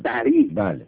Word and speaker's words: دری: دری صحبت میکنند، این زبان دری که بعضی وقتا هم دوری خودشون تلفظ میکنن،